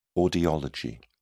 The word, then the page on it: audiology: British English